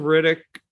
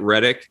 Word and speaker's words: Riddick: I will tell you, Chronicles of Riddick doesn't get enough credit. I like that film, even though you pronounced it Reddick, and that's a NBA player.